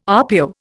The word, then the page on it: RPO: Indian